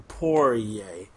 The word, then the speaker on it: Poirier: So I think now you pour a beer and then go yay, because you're about to drink a beer. Poirier. So that's how you say his name for those at home who are wondering and who wonder why I mispronounce it every show.